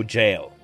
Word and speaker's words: gaol: And became the first prisoner in the newly built Wicklow Jail. Oh, fun fact. Even though the spelling is G-A-O-L, it's pronounced jail.